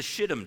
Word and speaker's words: shittim: Most people think, by the way, forgive my language, this is actually how you say it, it's the shittim tree, okay? It's Hebrew.